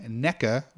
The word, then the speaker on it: NECA: The company NECA, which is, I think, how you pronounce it.